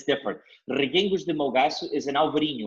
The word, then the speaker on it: Reguengos: I'll spell it for you. R E G U E N G O S, Reguengos. Ah, Reguengos.